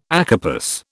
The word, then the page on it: acarpous: British English